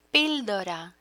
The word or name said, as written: pildora